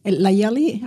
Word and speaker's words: Layali: Layali.